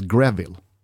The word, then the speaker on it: Greville: One text from Mr. Giggs said, I love you so much baby, it even blows my mind that your surname is Greville. It rhymes with Neville, who is one of my besties, and Breville, which is my favorite toasty maker.